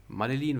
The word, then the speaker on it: Madeline: Aber Madeline, Madeline wird es ausgesprochen, Madeline Jono, die neue Single über dich, hat mich äh, tatsächlich gekriegt.